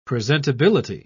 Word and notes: presentability: British English

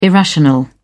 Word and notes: irrational: British English

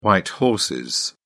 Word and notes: white horses: British English

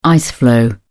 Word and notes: ice floe: British English

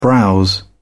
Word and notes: browse: British English

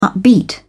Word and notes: upbeat: British English

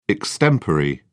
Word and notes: extempore: British English

